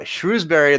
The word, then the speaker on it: Shrewsbury: There's a line of storms from Shrewsbury in the south up to Nantwich in the north.